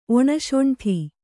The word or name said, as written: ಒಣಶೊಂಠಿ